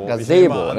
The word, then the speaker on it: Gazebo: Und wir waren auch mittendrin, als dann Gazebo, richtig ausgesprochen? – Gazebo, natürlich.